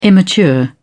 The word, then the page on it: immature: British English